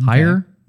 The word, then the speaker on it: Hyre: I think it's Mary Hare, H-Y-R-E, okay. Hire.